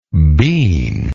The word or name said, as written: beanlike